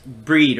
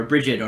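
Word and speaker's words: Brigid: But the centerpiece in the Gaelic tradition is Breed or Bridget or Bride, depending on which pronunciation.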